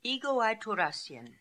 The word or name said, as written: Egoaitoorassian